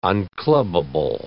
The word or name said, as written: unclubbable